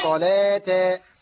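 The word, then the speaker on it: صلوٰة: وصلاة وزكاة، ملاحظة أن الواو فوقها ألف صغيرة. – نعم الألف هو الذي ينطق، ولكن الألف ينطق والواو لا تنطق في زكاة.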